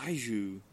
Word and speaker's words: Baijiu: Baijiu. – Baijiu.